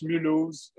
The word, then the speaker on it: Mulhouse: And I was in Mulhouse, but some people say Mulhouse too. – Mulhouse. – But it's pronounced Mulhouse.